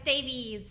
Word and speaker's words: Davies: Is it Davis? Is it Davies? I know sometimes with the E you don't pronounce it. – It's Davies, so it's I-E-S.